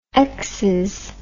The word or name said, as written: exxes